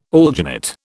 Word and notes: alginate: British English

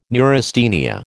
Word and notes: neurasthenia: American English

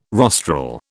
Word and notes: rostral: British English

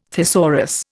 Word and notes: thesaurus: Indian

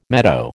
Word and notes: meadow: American English

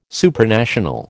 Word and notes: Supranational: American English

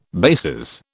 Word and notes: bases: British English

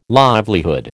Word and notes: livelihood: American English

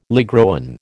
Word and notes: ligroin: American English

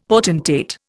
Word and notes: potentate: Indian

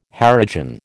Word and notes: Harijan: American English

